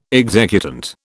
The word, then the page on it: executant: British English